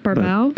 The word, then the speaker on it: Barbeau: Adrian Barboa, we're introduced, is a radio DJ. – Barboa? – How do you say it? Barbeau? – Barbeau. – Barbeau. – Boom, boom, Barbeau. – Boom, boom, Barbeau. Beau.